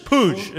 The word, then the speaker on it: Puig: It's just Puig.